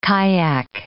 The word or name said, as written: kayak